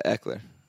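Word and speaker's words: Ekeler: Yeah, Eckler.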